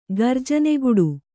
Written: ಗರ್ಜನೆಗುಡು

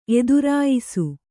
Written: ಎದುರಾಯಿಸು

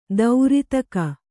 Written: ದಾರಿದೆಗೆ

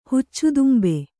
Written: ಹುಚ್ಚುತುಂಬೆ

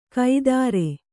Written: ಕೈದಾರೆ

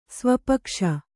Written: ಸ್ವಪಕ್ಷ

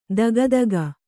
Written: ಥಕಥಾಕು

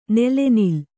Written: ನೆಲೆನಿಲ್ಲು

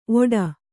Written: ಓಡ

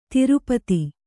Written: ಧಾರಾಪಾತ

